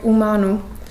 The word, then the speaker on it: humano: Beispielsweise wird das H nicht gesprochen, wie bei dem Wort humano, was so viel wie Mensch bedeutet.